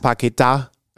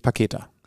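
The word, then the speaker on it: Paquetá: er Paqueta äh, ausgesprochen wird und nicht Paketa.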